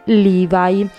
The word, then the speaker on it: Levi: Quindi da questo momento in poi non chiameremo più il nostro protagonista Loeb Strauss, bensì Levi, scritto Levi, esattamente come noi italiani lo pronunciamo.